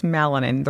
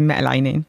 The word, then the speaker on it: Bencheqroun: It's melanin. The right way of saying it is melanin.